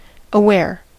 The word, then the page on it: aware: [ə.ˈwɛə(r)]; American English